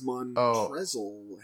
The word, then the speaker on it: Montrezl: It's Montrezl Harrell.